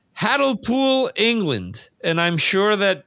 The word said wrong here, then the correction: Hartlepool: Sean, help us out with the pronunciation. Hartlepool, I guess. – No, Hartlepool. Hartlepool. Hartlepool. Yes. Hartlepool.